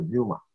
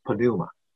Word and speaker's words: pneuma: 希腊文呢是 pneuma，那英文呢也是 p 不发音就是 pneuma 啊，但是希腊文是 pneuma，这个字是风也是灵。